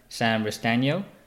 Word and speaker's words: Sam Restagno: Today on the podcast, I have Sam Restagno.